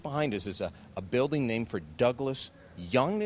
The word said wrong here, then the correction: Jung: is it? – Jung.